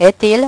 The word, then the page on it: et il: French